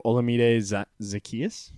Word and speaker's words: Olamide Zaccheaus: Number 2, Olamide Zaccheaus.